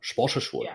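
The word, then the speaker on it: Spottiswoode: So though this movie was directed by Roger Spottiswood. I don't know if I'm pronouncing that right. It's a pretty cool name. Spottiswood. Yeah, that's a good way to say it.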